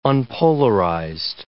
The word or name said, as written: unpolarized